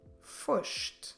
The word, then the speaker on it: foste: Tu foste. Tu foste. Como se escreve foste? F-O-S-T-E.